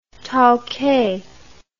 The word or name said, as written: towkay